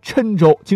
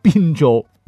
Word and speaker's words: 郴州: 还有郴州，经常被读成滨州。